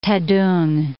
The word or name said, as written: Taedong